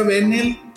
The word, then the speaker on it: Benell: Mira, se pronuncia Benel, pero nomás porque yo digo. Ese es el, pedo, Debería tener un acento.